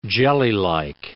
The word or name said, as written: jellylike